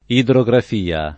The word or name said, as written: idrografia